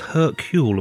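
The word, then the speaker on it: Hercules: It's Adam Roach, guys, best known from Secret History of Hollywood, Attaboy Clarence, and is it The Labours of Hercule? Am I saying that right, mate? – Yeah, that's right.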